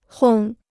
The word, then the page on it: 烘: hōng